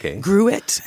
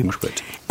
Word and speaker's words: Gruit: Maybe it's pronounced, it's like fruit with a G. So maybe Gruit. Anyway. – Gruit.